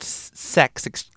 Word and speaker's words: CeX: It's not like, there's a bunch of places called Sex, C-E-X. Oh, damn it, that's probably why they spelt it C-E-X, because when people try to pronounce it, it comes across that way.